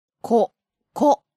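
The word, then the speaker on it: ここ: And here's an intonation point, always stressing, practice the pronunciation and the intonation when you learn these words.For koko, soko, and asoko, you'll notice that the pronunciation, there's a pattern.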